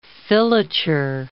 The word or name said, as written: filature